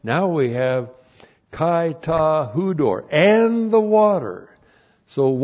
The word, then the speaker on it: hudor: So pneuma, God the Holy Spirit, and then we have kai ta hudor. Looks like that in the Greek. There's the conjunction chi, and then we have ta, the definite article, and then H-U-D, long O-R, hudor.